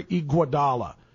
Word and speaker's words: Iguodala: It's Andre Iguodala. G-U, gua.